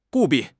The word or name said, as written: 务必